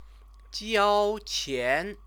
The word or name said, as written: jiaoqian